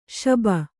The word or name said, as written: ಶುಭ